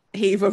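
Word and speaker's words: Hever: It rhymes with Beaver. – Heaver rhymes with Beaver.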